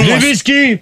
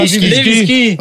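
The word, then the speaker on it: Lewinsky: À la question, que pensez-vous de l'affaire Levinsky? Donc, les Français ont répondu non. – Levinsky, pas Levinsky. – Ok, excusez-moi, prononciation.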